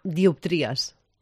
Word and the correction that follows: dioptria: La paraula està bé, però la pronunciació no. – Tampoc. – És una dioptria. – Ostres, diàptria. – La síl·laba tònica sobre la O.